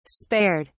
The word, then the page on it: spared: British English